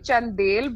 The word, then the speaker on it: Chandel: It's actually Chandel, but I can forgive you for that because it's just very complicated.